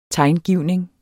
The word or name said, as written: tegngivning